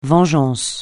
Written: vengeance

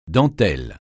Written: dentelle

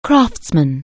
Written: craftsman

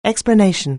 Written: explanation

